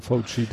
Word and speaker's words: Fauci: Wobei da, da kommt auch wieder rein, er hat auch erst Wahrwerbung gemacht, so von wegen, also erstmal De Fauci, jetzt bin ich wieder von dem Namen raus, äh, unsere Aussprache. Fauci? Ja, ich würde auch sagen Fauci.